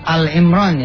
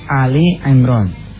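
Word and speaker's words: Ali Imran (آل عمران): Eh, bacanya jangan Al Imran ya, tapi Ali Imran. Di tulisannya itu Ali Imran.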